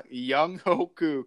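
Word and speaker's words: Younghoe Koo: I mean, you got to go Young Ho Koo. I mean. – Yeah, Young Ho Koo, correct.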